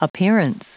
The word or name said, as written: appearance